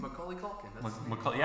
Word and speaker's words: Macaulay Culkin: Kevin McCulkin? How do you say his name? – Macaulay Culkin.